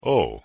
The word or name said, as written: oh